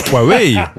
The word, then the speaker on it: Huawei: H-U-A-W-E-I. – Huawei. – Ah ben voyez. – Huawei. – Qui c'est qui avait raison? C'est bien Huawei.